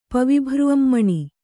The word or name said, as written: ಪವಿಭೃನ್ಮಣಿ